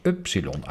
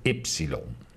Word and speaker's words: upsilon: In het Oud-Grieks werd de naam van de letter dan ook als upsilon uitgesproken, maar in het modern Grieks werd het ypsilon.